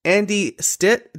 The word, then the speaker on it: Andy Stitt: Today, my guest is Andy Stitt.